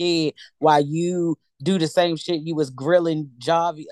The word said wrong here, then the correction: Jovi: whatever. I never say his name right. – Jovi.